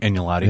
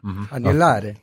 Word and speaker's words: agnolotti: And that was lamb aglinati. I can never say that right. – Agnolotti. – Hey, thank you. – Agnolotti.